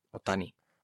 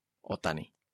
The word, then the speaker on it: Otani: En japonés todos los acentos son llanos. – ¿Y cómo se pronuncia acento llano? – Otani. – ¿Y sin acento? – Otani. – Muchas gracias. – Es que no hay acentos.